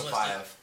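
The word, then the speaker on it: Mustafaev: Magomed Mustafiev. I can't pronounce Mustafiev. – Thank you. – Magomed Mustafiev.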